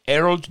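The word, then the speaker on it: Ayrault: El historiador militar estadounidense Theodore Errol Dodge, voy a deletrear Errol porque Theodore es un nombre, pero si quieren buscarlo, Errol Dodge. Errol se escribe A-Y-R-A-U-L-T. Y Dodge, pues, como el carro, D-O-D-G-E.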